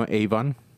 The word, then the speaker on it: avon: El hebreo es avon. Y cuando yo lo vi, no Avon. la Biblia no está vendiendo productos de Avon. es, la palabra hebrea avon.